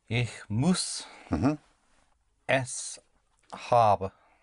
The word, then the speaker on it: habe: D-E-N-N, I don't have it. – Denn ich habe es nicht. – Denn ich ha, put the stress on the A, habe.